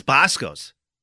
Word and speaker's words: Boscov's: I know, but I think it's silent. – Sure. – Bosco's. You can call and correct me, but I'm pretty sure it's Bosco's. – Bosco's? – I don't pronounce Bosco's. V is silent.